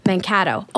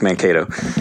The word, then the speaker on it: Mankato: He is an associate professor of psychology and co director of the Sexuality Studies program at Minnesota State University, Mankato. Oh my God, did I pronounce that right? – Close, Mankato.